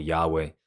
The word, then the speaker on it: Yahweh: And so we just simply threw in some vowels in there, and we call it Yahweh.